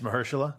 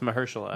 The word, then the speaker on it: Mahershala: It's Mahershala? – Yeah. – I've always wanted to do that. I love his work, but it's Mahershala.